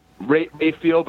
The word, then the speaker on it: Raphael: Is it Raphael, right? Your last name? – Rayfield, but I don't correct people.